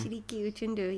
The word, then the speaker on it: Shiriki Utundu: And yeah, uh, as you mentioned, there's a Shu- Shikiri Utundu or however you say his name. – Shiriki Utundu. You'll make him angry.